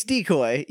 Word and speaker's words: decoy: Well, it's decoy. You emphasized the wrong syllable.